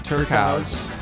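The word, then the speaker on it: Turkuaz: I think it's Turkaus. – Turkaus.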